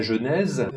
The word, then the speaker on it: genèse: Le « e », on ne va pas le prononcer, mais il faut quand même prononcer la dernière syllabe, parce que sinon, on a un « genet » et pas « genèse ».